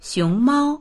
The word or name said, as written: xiong2mao1